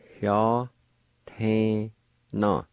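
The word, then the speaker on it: yatena: Right at the beginning of this word, there's a faint H sound, pronounced like this. Hya te na.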